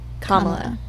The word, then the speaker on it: Kamala: Kamala. – Kamala Harris. – Is it Kamala Harris? I'm going to hear that. I'm going to say it's Kamala Harris. That sounds right.